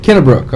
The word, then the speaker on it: Kennebrook: Kennebrook. Okay, yeah. – Good deal. Kathy Kennebrook, she is the Marketing Magic Lady.